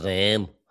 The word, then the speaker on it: Reims: Reims, som jeg tror, det hedder. Jeg er jo ikke den store fransktaler. – Jeg tror, du har, for det staves R-E-I-M-S, og det udtales Reims.